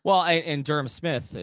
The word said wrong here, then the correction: Smythe: I've always pronounced his name incorrectly. But Smythe, by the way.